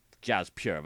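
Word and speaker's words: Djas Puhr: So it's D J A S. – Okay. – Jazz. I'm guessing it's a silent D. And P-U-H-R. Jazz Pure. That's what I'm going with.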